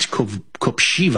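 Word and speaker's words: Kopřiva: Vit Koprija. Okay. – No, it's Vit Koprijiva, I believe you pronounce it, with a Z sound.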